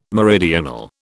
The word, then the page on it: meridional: British English